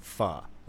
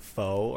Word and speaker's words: pho: Oh, she misspelled or mispronounced the word pho, like pho, the food. – Oh. – She pronounced it like faux or something like that.